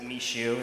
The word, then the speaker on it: Michaud: M-I-C-H-A-U-D, and actually, it's more of like a French last name, so I pronounce it Michaud, but most people pronounce it Mishu, and there's a lot of other iterations to that as well.